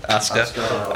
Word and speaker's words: Asuka: Asuka.